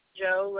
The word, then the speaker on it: Joe: Joe Liberator.